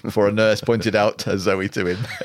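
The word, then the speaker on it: Zowie: According to Nicholas Pegg's A Complete David Bowie, Zoe is pronounced like the girl's name Zoe, intended as a masculine version of the Greek word for life.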